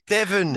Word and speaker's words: Devon: That, Devon, I've got it right. Devon, like Devon Loch, which like which you two are honestly spread eagling yourselves in front of the the finishing line.